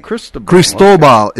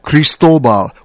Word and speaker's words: Cristobal: Cristobal is the proper pronunciation. Cristobal, which means Christopher.